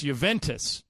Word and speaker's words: Juventus: If you can identify it, we're giving away a pair of tickets to see Real Madrid against Juventus. Am I saying that right, Jorge? – Yes, yes, you are.